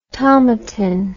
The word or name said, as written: tomatin